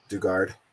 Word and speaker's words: Dugard: Dugard, I think I'm saying her name right. – Dugard.